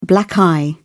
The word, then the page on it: black eye: British English